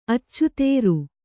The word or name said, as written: ಅಚಿತ್ರ